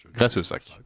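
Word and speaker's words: Graissessac: Alors, comment le prononce-t-on ? Grèce-Sac ou Grèce-Sac ? – Grèce-Sac.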